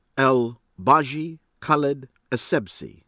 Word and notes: El Beji Caid Essebsi: American English

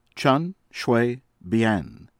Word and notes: Chen Shui-Bian: from Taiwan

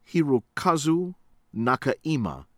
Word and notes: Hirokazu Nakaima: from Japan

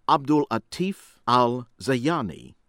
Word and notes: Abdullatif Al-Zayani: American English

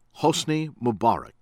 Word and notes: Hosni Mubarak: American English

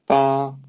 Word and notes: 巴: Mandarin Chinese

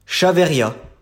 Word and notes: Chaveyriat: French

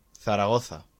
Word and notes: Zaragoza: Spanish

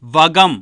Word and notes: வகம்: Tamil

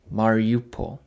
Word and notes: Mariupol: English

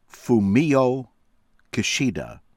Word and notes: Fumio Kishida: American English

